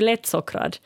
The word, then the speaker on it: lättsockrad: Att säger du lättsockrad, så då skrivs det ihop. Medan om du vill säga då, du läste ju det olika också, lättsockrad. Och då uttalas det som två ord.